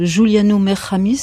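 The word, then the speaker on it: Giuliano Mer-Khamis: Vous dédiez d'ailleurs votre film à un certain Giuliano Merhamis, je ne sais pas si on prononce comme ça. – Absolument.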